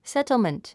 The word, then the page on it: settlement: sétlmənt; セトルメント